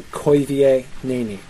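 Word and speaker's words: Koivienéni: Oi is a diphthong, that's oi diphthong. So koi vie. We know that the I and the E are pronounced separately because you've got the diaresis, the two dots above the E, right? Koi vie neni. The E would be pronounced A, and it's got an accent there, so you know it's a long vowel and not a short vowel, so it's not nini. It's a long vowel, so it's neni. So koi vie neni would be how that's pronounced.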